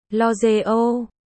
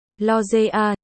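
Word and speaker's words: L'Oréal: Dù sao, lúc mới tới tôi luôn đọc là L'Oreal, sau này mới biết, phải đọc là L'Oreal, chữ A, đó từ đâu chui ra cơ chứ?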